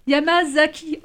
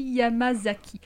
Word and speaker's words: Yamazaki: Alors je vais essayer de ne pas écorcher le nom du réalisateur japonais qui s'appelle Takashi Yamakazi. Ah, Yamakaki ah, Yamazaki. Oh là je vais arriver. – C'est le festival. – Festival de la faute. Takashi Yamazaki.